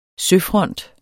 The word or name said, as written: søfront